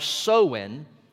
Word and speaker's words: Samhain: The Gaelic or Celtic festival of Samhain, that's how it's pronounced, is probably the source of our present-day Halloween celebration.